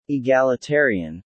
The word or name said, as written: egalitarian